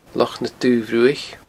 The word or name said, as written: Loch na Dubh Bhruaich